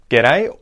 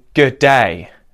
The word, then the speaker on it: g'day: The other one is saying g'day, where you emphasize both syllables pretty equally, okay? So you say g'day, g'day.